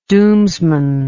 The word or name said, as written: doomsman